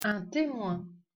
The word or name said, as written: Temoin